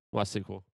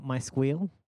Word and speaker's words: MySQL: What about MySQL? How do you say that one? – MySQL. – Not my squeal? My squeal? – My squeal? No, my squeal? I don't, maybe if the logo was a pig.